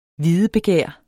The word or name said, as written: videbegær